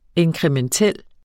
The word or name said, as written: inkrementel